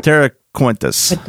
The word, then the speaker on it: Terra Cruentus: And just the whole, you know, the whole Terra Quente. Is that, am I saying it right? – Terra Cruentus. – Cruentus. Okay. Wow. C-R-U. That's weird in my mouth, but anyway.